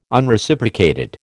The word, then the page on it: Unreciprocated: American English